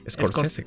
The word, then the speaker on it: Scorsese: De hecho, esta peli yo siento que va a ser muy, muy parecida a la película New York, New York de Scorsese o escocer, ¿cómo se pronuncia escocerse? No sé Scorsese, cabrón. Scorsese, cabrón. Scorsese. No sabes hablar, deja la droga, amigo.